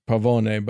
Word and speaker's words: Pavone: So Frank Pavone, I always want to pronounce it Pavone, but it's Pavone, kind of got famous in 2016 when he released a video of himself.